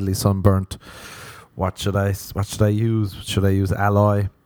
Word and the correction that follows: aloe vera: Is that how you, I presume that's not how you pronounce it. Alloy? Aloha? Let's do the old pronunciation. Google. Alloy? No. That's one of those. Alloy. Oh, aloe vera. Alloy, aloe vera.